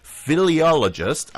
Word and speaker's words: philologist: Now, he was an English writer and academic philologist. I hope I'm pronouncing that correctly. I did not. Philologist. That's how that's pronounced.